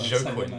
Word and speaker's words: Joaquin: Yeah, I didn't know how to pronounce Joaquin, so I just thought Joaquin.